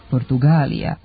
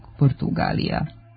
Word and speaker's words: Portugalija: Nedopustivo je u istoj pisanoj ili govornoj celini koristiti čas jedan, čas drugi oblik i duži izgovarati sa dugosilaznim akcentom na trećem slogu Portugalija umjesto pravilnog Portugalija.